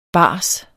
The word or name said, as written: bars